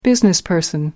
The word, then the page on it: businessperson: American English